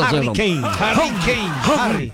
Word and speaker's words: Harry Kane: Fue el que anotó el día de hoy el Harry Kane. Harry. Como en la película Harry Potter.